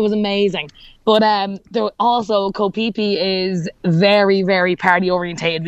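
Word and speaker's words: Koh Phi Phi: It's actually called Koh Phi-Phi, but it's spelled P-H-I, so my Irish accent just can't seem to get the pronunciation correct, and it's just embarrassing for myself.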